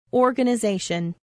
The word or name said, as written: organization